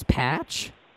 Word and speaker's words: Patch: My name is Patch. – P-A-T-C-H? – Correct.